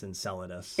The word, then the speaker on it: Enceladus: No, I think you're wrong. Can you spell it? – Enceladus. – I think it's Enceladus. – Enceladus. Yeah. That makes way more fucking sense.